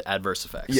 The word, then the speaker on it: Adverse Effects: Okay, so the name, at least we pronounce it Adverse Effects. – Yeah, exactly. – Just like the two words.